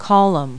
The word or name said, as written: column